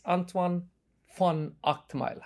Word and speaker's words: Antoine van Agtmael: Those of you who are tuning into this episode of FO Podcasts should know that my guest is Antoine von Agtmael. Have I got your pronunciation correct? – Absolutely, yes.